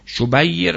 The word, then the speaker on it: شبیر: این شبیه رو شبر رو قبلا گفتیم که اسامی فرزندان هارونه که به عربی برگرداندن میشه حسین و حسن، که بعضی میگن شبیر و شبر، اشتباه تلفظ شبیر و شبره، شبیر و شبر تلفظش هم جناب نراقی پدر، نراقی پدر در کتاب مشکلات العلوم تلفظش صحیحه، شبیر و شبر رو آورده.